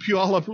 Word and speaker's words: Puyallup: Only people who lived near Puyallup can pronounce it. It's a odd name. Puyallup.